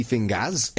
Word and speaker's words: Fingaz: And what do you think of Sticky Fingaz? – It's spelled F-I-N-G-A-Z. It's got to be pronounced Fingaz, right? – Yeah, I 100% agree.